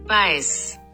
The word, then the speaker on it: Weiss: 我让 Google 小姐念一下正确的发音。 Vice，